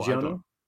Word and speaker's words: Giono: and it is Occupation Journal by Jean Genot. I hope I'm saying that right. Is that how you say it? Oh, Giono? – yeah, something like that.